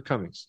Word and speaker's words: Comings: And uh, I have um, I'm on social media on Facebook and Twitter and Instagram, and on all of those I'm Andrew Comings.